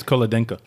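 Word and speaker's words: Cholodenko: I think the H is silent. – Chulodinko? – How do you guys know? – I think it's Chulodinko.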